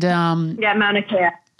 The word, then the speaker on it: Mauna Kea: But she does a lot of activism for, I hope I'm pronouncing it correctly, but the Mauna Kea. And Mauna Kea. – Mauna Kea.